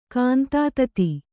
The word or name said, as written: ಕಾಂತಾತತಿ